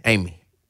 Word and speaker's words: Amey: Uh, like Amy? Amy. The yep. third. – A-M-E-Y.